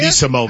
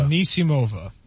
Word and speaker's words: Anisimova: Anisimova. – Anisimova. – How do you say it? – Anisimova. – How'd you say it? – Anisimova. – Anisimova.